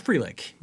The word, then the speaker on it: Frelick: Sal Frelick, am I saying his name right? – It's Frelick. Yes.